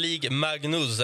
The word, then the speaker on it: Ligue Magnus: Alltså, den heter alltså, heter den Ligue Magnus, eller hur uttalar man där? – Ja, det stämmer.